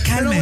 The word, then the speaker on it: Calment: Tú así dilo, Calment.